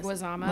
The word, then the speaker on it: Leguizamo: how do you say Leguizamo. – Leguizamo, who is amazing.